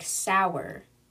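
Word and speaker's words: Sauer: And so he offers her one of his Sig Sauers, whatever. I'm going to figure out how you pronounce that, okay? Okay, I've been saying it wrong this whole time. Apparently it sounds something like sour, like S-O-U-R.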